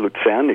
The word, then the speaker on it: Luzern: I was born in Luzern, exactly, in the Swiss part or the German part of Switzerland. Mm-hmm. And yes, that's where. Have you visited? And you know how to pronounce Luzern very well.